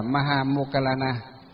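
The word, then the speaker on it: Moggallāna: Duyên khởi của đại lễ Vu Lan được bắt đầu từ tôn giả Maha Mục Kiền Liên, tiếng Phạm âm đọc là Maha Mokkalana.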